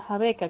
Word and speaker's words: rabeca: Y realmente el violín que utilizaban antiguamente se llama jabeca, rabeca se escribe, ¿no? Y se pronuncia jabeca, que es un violín hecho artesanalmente, donde pues lo ejecutaban, ¿cierto?